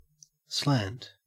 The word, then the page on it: slant: Australian English